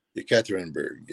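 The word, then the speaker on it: Yekaterinburg (Екатеринбург): Yekaterinburg, is that how to say it? – Yekaterinburg, yep.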